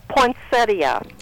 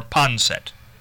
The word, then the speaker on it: poinsettia: Now, but there is an IA on the end, yes. So it is pronounced poinsettia.